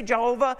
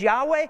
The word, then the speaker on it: Yahweh: We say Jehovah, the better pronunciation is Yahweh, but he says Jehovah Jireh.